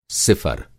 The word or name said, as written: सिफ़र